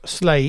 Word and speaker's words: SLA: And I'm very pleased because for years I have thought, is it Slay Industries or is it SLA Industries? And they very clearly say at the beginning of this Kickstarter, first paragraph, pronounce Slay Industries.